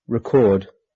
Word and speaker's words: record: E.g., conduct, contest, decrease, increase, export, import, object, record, transport. Are used both as nouns and verbs. As nouns, the stress is on the first syllable. As verbs, the stress is on the second syllable.